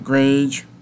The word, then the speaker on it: Grage: And replacing Zach Grage, Zach Grage rhymes with Gage, with a Gage with no R, G-A-G-E, Grage, Zach Grage, G-R-A-G-E,